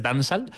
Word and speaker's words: dancehall: Como no es la H, como la H no se pronuncia, es dancehall.